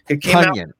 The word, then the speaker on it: Tonyan: Tonyan. – Because he said it rhymes with Funyan, like I got a bunion on this Funyan.